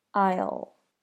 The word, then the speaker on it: I'll: I'll. I'll. I'll. Do you hear that dark L sound at the end of I'll?